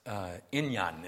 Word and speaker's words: inyan: The word task, inyan is how it's pronounced in the original.